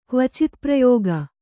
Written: ಕ್ವಚಿತ್ಪ್ರಯೋಗ

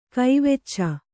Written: ಕವಿಚು

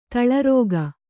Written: ತಲಾರಿಕೆ

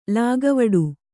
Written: ಲೆಕ್ಕವಿಡು

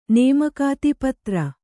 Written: ಪುತ್ರಿ

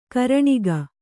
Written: ಕರಣಿಗ

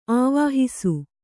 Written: ಆವಹಿಸು

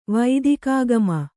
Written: ವೈದಿಕಾಗಮ

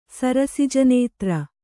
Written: ಸರಸಿಜನೇತ್ರ

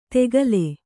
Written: ತೆಗಲೆ

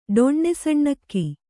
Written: ಡೊಣ್ಣೆಸಣ್ಣಕ್ಕಿ

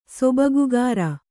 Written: ಸೊಬಗುಗಾರ

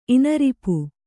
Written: ಇನರಿಪು

